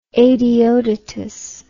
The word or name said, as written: Adeodatus